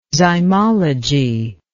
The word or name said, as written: zymology